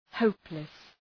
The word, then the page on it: hopeless: ‘həʋplıs